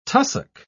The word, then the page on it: tussock: British English